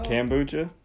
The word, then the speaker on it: kombucha: Kombucha? – Kombucha.